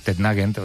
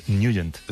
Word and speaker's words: Nugent: Hombre, siempre lo hemos llamado en el barrio Tednagent o Tednagent. – Claro, ¿verdad? Yo era de Tednagent. – Pero la pronunciación correcta es Nugent. – Te fuiste a Washington y descubriste que era Nugent.